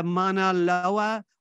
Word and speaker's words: Mauna Loa: So you know how we discussed that the Mauna Loa was erupting about 2 weeks before. – Good pronunciation. Just like, yeah, like a true Hawaiian.